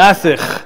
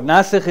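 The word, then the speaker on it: Nasikh (ناسخ): Nasikh. Nasikh. Yeah, like you're clearing your throat. Nasikh is correct, yeah?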